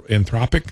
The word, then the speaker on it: Anthropic: A lead researcher at Anthropic, that's how you say it.